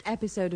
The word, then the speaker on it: episode: In the first one, remember to put the stress on the first E of episode. – The first episode of this soap opera was broadcast in 1955.